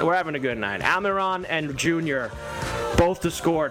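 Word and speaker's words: Almirón: Almiron.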